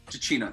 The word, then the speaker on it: Giacchino: We figured out exactly how we pronounced it. Michael Giacchino. – Giacchino, thank you. – Giacchino, Mike, yes.